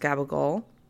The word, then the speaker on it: capicola: I don't know how you guys pronounce it, but my aunt told me it's pronounced gabagol. I don't think that's how you actually pronounce it in Italian, but that's just how she taught me, which later found out I always used to call it capicola. Because I'm American and that's how I read it.